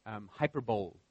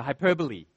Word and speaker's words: hyperbole: I was studying English in university before I realized that you don't pronounce it hyperbole. It's a hyperbole.